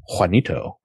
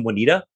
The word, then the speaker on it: Juanito: who none of the white people can seem to quite get his name right. – Juanito, right? – yeah. But one of the guys keeps replacing a vowel or something. And Juanito. – At one point calls him Juanita.